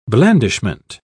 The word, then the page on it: blandishment: British English